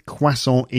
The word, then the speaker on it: croissant: How do you say croissant in your language? In English, we would say, some English people say croissants, but I think it's croissant. And the thing is that in French, the plural of croissant, one croissant, the plural is croissant.